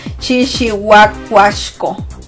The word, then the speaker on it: Chichihuacuachco: Chichihuacuachco, así se escribe. Es como Chichihuahua, pero no Chihuahua, ¿no?